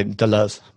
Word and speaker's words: Deleuze: You would say Deleuze, but yeah, that's fine.